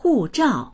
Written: hu4zhao4

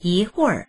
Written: yi2huir4

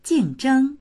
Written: jing4zheng1

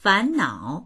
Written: fan2nao3

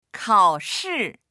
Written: kao3shi4

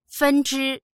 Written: fen1zhi1

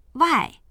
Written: wai4

